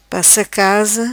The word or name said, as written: Passa casa